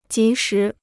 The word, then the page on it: 及时: jí shí